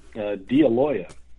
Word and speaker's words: Dealoia: It's a tricky Italian name. Dealoia.